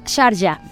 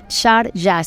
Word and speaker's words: Sharjah: Sharjah, así se dice y está todo el mundo. ¿Cómo se dice el país invitado de honor? Sharjah. Se escribe Sarja o Sharjah a lo mismo, pero es la cultura árabe y Sharjah como países invitados de honor.